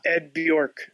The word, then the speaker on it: Bjork: It's Ed Bjork. – Ed Bjork. Okay. – Bjork, like Bjork.